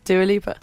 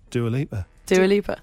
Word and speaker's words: Dua Lipa: Dua Lipa told me herself that's how you pronounce her name.